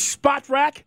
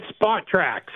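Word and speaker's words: Spotrac: It is Spot Rack. Tell us, is that correct? – It's Spot Track. So it's like, take the word spot and the word track and mash them all together.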